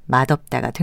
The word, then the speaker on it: 맛없다: 맛의 받침 발음인 디귿에 없다가 연결돼서 맛없다가 된 것입니다.